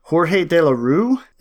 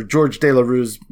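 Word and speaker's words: Georges Delerue: It was Jorge De La Rue. Is that how you say his name? – It's just George De La Rue.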